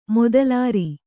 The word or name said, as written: ಮೊದಲೇರಿ